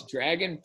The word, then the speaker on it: Dragan: Oh, it's Dragan Petrovic. And Dragan is spelled D-R-A-G-A-N, and he is Serbian.